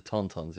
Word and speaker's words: Tauntauns: Again, I can't, forgive me if this is pronounced wrong, but Tauntauns, is it Tauntauns that they ride? – The Tauntauns, yeah.